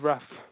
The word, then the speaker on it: Raf: So you spell it R-A-F-A-E-L, but English people can call me Raf because it's easier.